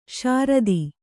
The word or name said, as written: ಶ್ರೋತ